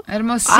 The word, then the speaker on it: Hermosillo: He's in, I don't want to say it wrong, Hermosillo? – Hermosillo!